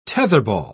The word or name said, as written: tetherball